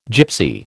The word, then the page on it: Gypsy: American English